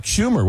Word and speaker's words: Schumer: I believe that was Chuck Schumer, was it not? – Yes. Yes. Yeah. The senator's name rhymes with rumor.